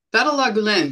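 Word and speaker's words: Gülen: Fethullah Gulen.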